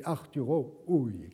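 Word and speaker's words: Arturo Ui: Hui se prononce en allemand Hui, c'est Arturo Hui qu'il faut dire.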